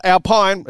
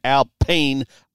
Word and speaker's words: Alpine: Not Alpine. It's spelled Alpine, but it's an Alpine A110.